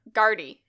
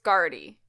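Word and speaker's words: Gardaí: Guardi. Yeah, the plural is Guardi.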